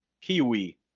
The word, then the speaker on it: Keywe: Il gioco si chiama Kiwi. scritto K-U-E. Ed è un gioco... – La vuole dare, no? – Sì, esatto, K-U-E. – K-U-E. – K-U-E. – Ma come? – Kiwi, si legge Kiwi. – Ma... – Sì. Ed è un gioco... – K-W-E-E? – K-E-Y-W-E.